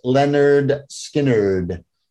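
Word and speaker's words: Lynyrd Skynyrd: Pronounced Leonard Skinnerd.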